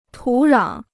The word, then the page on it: 土壤: tǔ rǎng